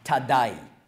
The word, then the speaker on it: Thaddai: Also sein voller Name ist auf Hebräisch ausgesprochen Yehuda Thaddai. Thaddai ist das gleiche wie Thaddäus, griechische Aussprache.